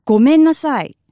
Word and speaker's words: ごめんなさい: You can also use the phrase ごめんなさい。